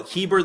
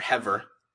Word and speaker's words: Heber: Now, Heber the Canaanite, or Hever, however you want to pronounce it,